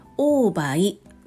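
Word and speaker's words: 黄梅: オーバイ黄色い梅と書いてオーバイって読むん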